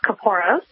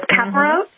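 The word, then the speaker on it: Capros (כפרות): Well, there are several pronunciations. We tend to stick to the pronunciation of Capros, but you might hear others refer to it as Caparos or Caparot. So you'll hear several incarnations depending on who you speak with, but we tend to just say Caparos.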